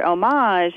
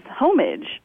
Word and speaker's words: homage: And she called me one day and said that a newscaster had pronounced the word homage as homage.